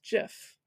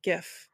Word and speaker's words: GIF: It's pronounced GIF not GIF.